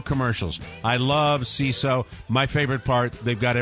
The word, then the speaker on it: Seeso: CISO, it's spelled S-E-E-S-O.